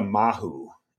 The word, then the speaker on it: mahu: How about a mahu? – Spell it. – M-A-H-U. – M-A-H-U, mahu.